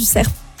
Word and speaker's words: cerf: Figurez-vous que j'étais dans un collège vert, donc spécialité environnement, et on nous amenait chaque année écouter le brame du cerf. Okay. Mais du coup, arrête-moi si je... Cerf. – Ah oui, cerf. Ouais. Ah, dans le sud-ouest, on prononce toutes les lettres. – Et dis le F.